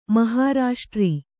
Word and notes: ಮಹಾರಾಷ್ಟ್ರ: mahārāṣṭra

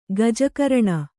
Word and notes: ಗಜಕರ್ಣಿ: gaja karṇi

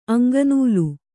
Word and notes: ಅಂಗನೂಲು: Kannada

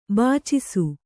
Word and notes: ಬೆಚ್ಚಿಸು: beccisu

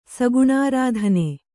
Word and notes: ಸಗುಣಾರಾಧನೆ: Kannada

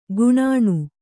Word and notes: ಗುಣಣೆ: guṇaṇe

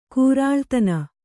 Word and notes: ಕೂರಾಳ್ತನ: Kannada

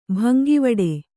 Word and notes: ಭಂಗವಡೆ: bhangavaḍe

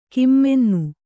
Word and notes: ಘಮ್ಮೆನ್ನು: ghammennu

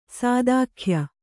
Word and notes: ಸಾದಾಖ್ಯ: kannada

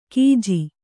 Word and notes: ಕೀಜಿ: Kannada

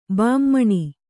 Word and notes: ಭಾಮಿನಿ: bhāmini

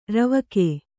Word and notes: ರೂವುಕ: rūvuka

